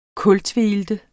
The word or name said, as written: kultveilte